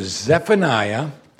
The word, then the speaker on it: Zephaniah: Go with me to Zephaniah.